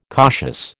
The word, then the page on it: cautious: American English